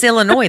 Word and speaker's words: Illinois: It's Illinois, though, isn't it? – Illinois. Yeah, the S is silent. – Like the L in salmon.